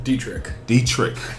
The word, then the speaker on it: Dietrich: Dietrich. The German undertones.